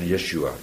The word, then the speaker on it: Yeshua: Jehoshua of nog korter, Yeshua. Yeshua, dan verlegt men de klemtoon ook.